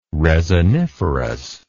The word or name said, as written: resiniferous